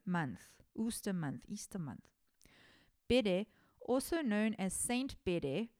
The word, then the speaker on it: Bede: The venerable Bede, I don't know how to pronounce his name. It's B-E-D-E, Bede, Bede. I'm going to call him Bede.